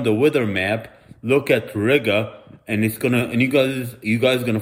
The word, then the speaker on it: Riga: So I was walking around Riga, which rhymes with nigga,